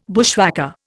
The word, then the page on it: bushwhacker: Indian